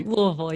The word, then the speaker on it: Louisville: Did I say that correctly? Louisville, Kentucky? – Louisville, yeah.